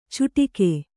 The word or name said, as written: ಚುಟಿಗೆ